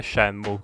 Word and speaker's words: Shenmue: Ok, mentre aspettavo che la sigla di introduzione finisse, ho aperto il telefono al volo per vedere la pronuncia giusta e in teoria dovrebbe essere Shenmue, quindi meschino me.